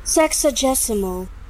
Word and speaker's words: sexagesimal: Sexagesimal. – Sexagesimal. – Oh, sexagesimal. Nice. – Do you know what that is? Shut up. – What a nice word. – Sexagesimal.